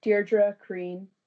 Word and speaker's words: Deirdre Crean: It'll be anywhere that you listen to music, probably, under my name, Deirdre Crean. That is spelled Z-E-I-R-D-R-E-C-R-E-A-N.